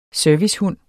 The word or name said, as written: servicehund